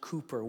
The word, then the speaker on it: Cowper: It actually is pronounced Cooper, William Cooper.